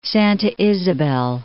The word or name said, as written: Santa Isabel